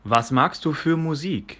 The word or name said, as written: Musik